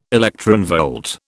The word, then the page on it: electronvolt: British English